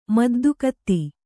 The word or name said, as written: ಮುದ್ದುಗಾತಿ